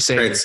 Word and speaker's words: Sager: There's a scene where Is it Sager? Is that how you say his name? – Craig Sager.